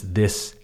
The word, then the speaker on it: this: A good one to practice these two different vowel sounds is this and these.